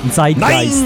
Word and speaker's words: Zeitgeist: Ben, la vraie prononciation en allemand, ça serait Zeitgeist. Faut mettre un T en avant du Z. – Zeitgeist.